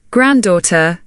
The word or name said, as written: granddauther